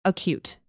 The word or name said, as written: acute